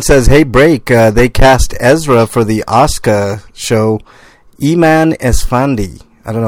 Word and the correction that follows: Ahsoka: Oh, they cast Ezra for the Ahsoka show. God, I had to get up chat to see what they're talking about. – You pronounced it wrong.